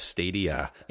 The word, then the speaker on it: Stadia: I think called Stadia. I'm not actually sure if that's how you pronounce it or not, but I believe that is. – Yeah, like the plural of Stadia. – Yes, Stadia.